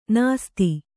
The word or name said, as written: ನಿಸದ